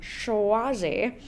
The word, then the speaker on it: Suozzi: But regardless, you may have seen this announcement that Representative Tom Suozzi is... – I'm so glad you pronounced it that way, because that's just like what that name deserves.